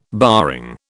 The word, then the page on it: barring: British English